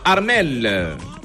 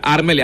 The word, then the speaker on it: Armel: Il y a Armel et Armel, hein. Armel, premier Armel au masculin, c'est A-R-M-E-L, tout simplement. Deuxième Armel, c'est A-R-M-E-2-L-E. Voilà, c'est ça la signification. Mais ça se prononce de la même manière, hein.